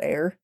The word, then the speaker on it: Eyre: If I hadn't heard Jane Eyre uh, spoken at me multiple times in high school for the, you know, classic literature, I wouldn't have known to pronounce it Eyre.